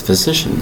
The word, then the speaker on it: physician: The word is physician, spelled P-H-Y-S-I-C-I-A-N. Physician. Three separate syllables. You might say, why isn't there an F in there for the F sound? We use a PH for the F sound in this case, spelled P-H-Y-S-I-C-I-A-N.